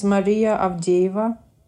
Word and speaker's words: Maria Avdeeva: Yes, my name is Maria Avdeeva.